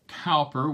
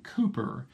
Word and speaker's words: Cowper: William Cowper, we would pronounce his name in the United States. I suspect in England they would pronounce the name William Cowper.